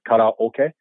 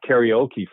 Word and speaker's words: karaoke: but it's kata is like in karate. – Yep. – Kata yep. – means empty. Okay. And oke is orchestra. So empty orchestra, kata oke. – Oh. – That's how they pronounce it. So it's not karaoke, first of all.